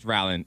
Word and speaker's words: Rowling: It's Rowling.